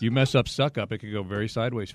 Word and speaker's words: Succop: Zoc, as you would know, S-U-C-C-O-P is pronounced suck up.